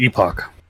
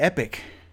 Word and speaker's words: epoch: It's an epoch. – The epoch. I thought I always called it epic. – That's not even that. – Epoch. Maybe. Yeah, epoch. – That's not even how it's spelled. There's no I in it.